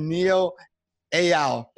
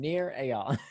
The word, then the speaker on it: Nir Eyal: I have Mr. Nir Eyal. Did I say it right? I didn't want to mess it up. – Close enough. Try one more time. Nir Eyal. – Nir Eyal.